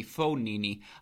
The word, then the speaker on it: Fognini: Fognini minus 115 over Griekspoor. Excuse me, Fognini. I apologize for the American pronunciation there.